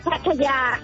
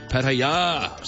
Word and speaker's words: Pattaya: The correct pronunciation is Pattaya. – Pattaya.